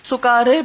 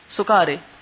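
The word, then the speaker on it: سُكَارَىٰ: لیکن دوسرے لہجے میں اس کو سکارے بھی پڑھا گیا ہے، سکارے. اب یہ لکھنے میں تو بالکل ایک جیسا ہے دونوں، فرق کچھ نہیں، لیکن بولنے میں اس میں فرق ہے.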